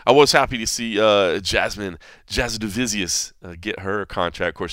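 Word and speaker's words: Jasudavicius: By the way, Jasmine Divizius is the proper pronunciation of her last name, so they were saying it right on there.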